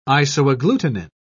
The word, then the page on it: isoagglutinin: British English